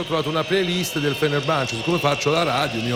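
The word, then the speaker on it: Fenerbahçe: Sì, è proprio la playlist del, come si pronuncia, scusate, Fenerbahce.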